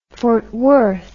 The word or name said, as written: Fort Worth